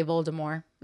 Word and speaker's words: Voldemort: Yeah, no, I do know that she was like, no, the T is silent, and they were like, well, we've already filmed half of these. – The original audiobooks say Voldemort.